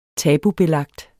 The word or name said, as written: tabubelagt